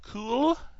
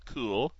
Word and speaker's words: Cuil: So I go to their site, Cool, and that's spelled C-U-I-L, Cool.